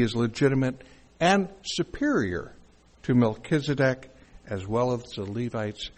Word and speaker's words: Melchizedek: specifically Melchizedek.